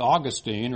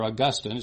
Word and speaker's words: Augustine: And so much of the Reformation was based on what Augustine, or Augustine, it's pronounced both ways, both are considered correct, but what he taught.